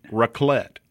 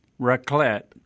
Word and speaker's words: raclette: Raclette. – Raclette.